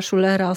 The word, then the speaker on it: Schuller: Mam przed sobą książkę Konrada Schullera, Ostatni dzień Borowa. Konrad Schuller, właściwie tak się wymawia jego nazwisko, to były korespondent Frankfurter Allgemeine Zeitung w Polsce.